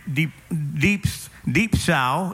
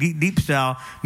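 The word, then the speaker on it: deep sow (διψῶ): And the word is deep sow is the how they pronounce it in Greek, deep sow, meaning I am thirsty.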